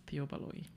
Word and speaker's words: Theo Baloyi: who is Theo Baloyi? – Jeez.